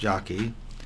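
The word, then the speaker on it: Jaki: Indeed, Stanley Jakey," sometimes I've heard it pronounced Jockey, "and others have argued that it was the metaphysical framework of medieval Catholicism which made modern science possible in the first place."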